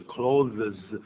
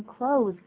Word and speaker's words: clothes: 嗯，就因为按照标准说法是 clothes，但难发音，所以实际上的 z 的音不见了。就直接发 z 的音出来。 – 没错，这个 z 的音通常把它省略掉，我们就念成 close，跟关门的关 close 是同音字。